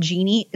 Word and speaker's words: Geeni: There's one by a company called Genie. It's spelled weird, G-E-E-N-I.